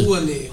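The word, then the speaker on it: Rouanet: Ruaner.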